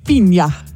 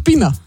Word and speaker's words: Piña: Übrigens, in Spanisch, Piña Colada. – Ja, habe ich dir zu wenig Spanisch ausgesprochen. – Du hast Pina gesagt.